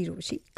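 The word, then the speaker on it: Rochie: मेरा नाम है मिर्ची रोशी, काम है आपसे प्यार करना, नाम की स्पेलिंग है RO CHIE.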